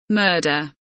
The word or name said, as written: murder